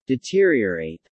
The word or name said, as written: deteriorate